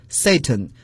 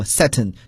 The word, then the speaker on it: Satan: 好，接下来看另外一个词 Satan，英国人呀把它读作 Satan，美国人呢有时把它读作 Satan，就是一种语言变体了。其实 Satan 都是对的。